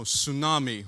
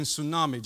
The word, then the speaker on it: tsunami: It's like, I know, tsunami. Why is there T in tsunami? Makes no sense, does it? For foreigner, it's confusing, right? All my Brazilian friends, it's confusing to have a T in tsunami.